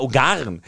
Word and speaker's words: Ungarn: Erste Frage, das hat mich hier wirklich schon beim Spiel, du sagst immer Ungarn. Für mich sind das die Ungarn, oder? – Die Ungarn. Du hast recht. – Die Ungarn. – Die Ungarn. – Ja, gut. Aber Betonung auf Ungarn. – Ja, die Ungarn. Also du darfst sie auch Ungarn nennen.